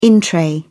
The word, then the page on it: in-tray: British English